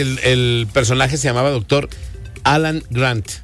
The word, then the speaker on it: Alan Grant: Alan Grant.